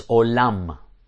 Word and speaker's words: olam: In fact, in Hebrew, the Hebrew term for world is olam. Olam is how you say world in Hebrew.